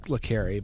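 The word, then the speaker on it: le Carré: Specifically, when you say spy novels, it really goes to John le Carre, John le Carre, however you want to pronounce it. – Yeah, something like that. – I believe it's pronounced le Carre, but anyway, he wrote Tinker Tailor, Soldier Spy.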